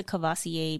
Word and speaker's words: Courvoisier: at least learn how to pronounce properly. Like and Courvoisier is one of them.